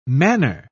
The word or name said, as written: manor